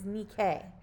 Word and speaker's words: Nike: The proper way to say it in ancient Greek is Nike the goddess of victory.